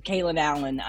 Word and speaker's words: Kalen Allen: So you created, now you have your own podcast called Raised by Ricki, which you co-host with, it's Kalen Allen. Am I saying his name properly? – Yes. The indomitable, like lovable Kalen Allen.